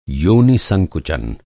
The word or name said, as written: संकुचन